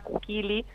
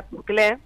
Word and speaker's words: Kukily: En las redes las van a encontrar como Kukili Colectivo, eh, se escribe, lo estoy nombrando mal para que se entienda cómo se escribe, es K-U-K-I-L-Y, Kukili aunque se pronuncia Cuclé.